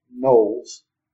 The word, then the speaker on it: Knollys: You will note that his name orthographically has been standardized to be spelled K-N-O-L-L-Y-S, but it is pronounced Knowles.